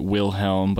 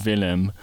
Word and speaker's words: Wilhelm: So, by way of explanation, Young Royals is a Netflix series that centers around a teenage boy named, well, in English we'd pronounce it Wilhelm, but they call him Willem, who's the crown prince of Sweden.